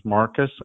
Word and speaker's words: Marcus: Yes, my name is Marcus, M-A-R-C-U-S, and my last name is spelled I-L-L-E,